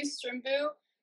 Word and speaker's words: Strimbu: My name is Zoe Strimbu.